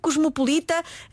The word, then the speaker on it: cosmopolita: O adjetivo cosmopolita não tem qualquer acento gráfico, pronuncia-se com as vogais fechadas, cosmopolita, e é a única forma que está atestada em português.